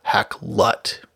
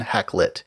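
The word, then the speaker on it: Hakluyt: but something more like a schwa, Hacklut, rather than Hacklit. They also offer the stranger Hacklewit.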